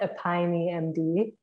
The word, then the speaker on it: Epione: Is it Epione MD? – We call it Epione MD. – Epione, okay. E-P-I-O-N-E MD. – Yes. – Epione. Okay. So what is that all about? – So Epione MD,